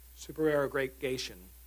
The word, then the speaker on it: supererogation: Free cup of coffee to anyone who can pronounce this word. Supererogation. Well, there you go. Super. So let's try it. Super. It's like erogation.